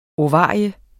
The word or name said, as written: ovarium